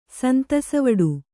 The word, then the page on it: ಸಂತಸವಡು: kannada